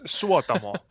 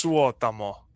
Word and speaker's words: Suotamo: I mean, it's Suotamo, but it's yeah, that's Suotamo. In Finnish, every name is pronounced as it's spelled every time the same way.